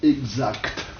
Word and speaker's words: exact: L'ancienne prononciation, si vous voulez, l'ancienne, quelle est-elle? – Exact. – Exact, voyez-vous, sans que te, voyez-vous.